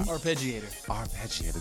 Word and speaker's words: arpeggiator: Just I'm always gonna say this word wrong. Your arpeggiator? How do you say it? – Arpeggiator. – There you go.